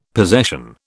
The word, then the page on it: possession: British English